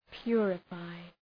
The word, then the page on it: purify: ‘pjʋrə,faı